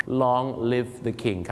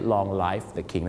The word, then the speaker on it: long live the king: long live the king ครับผมขอให้ทรงพระเจริญไม่ใช่ long life the king